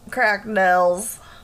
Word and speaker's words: Cracknells: It's spelled C-R-A-C-K-N-E-L-L-S. Cracknells. – Oh, like the sound of a bell. Like a death knell. A crack knell.